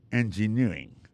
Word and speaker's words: engineering: Engineering, ça, l'accent tombé le même sur avant dernier syllabe là.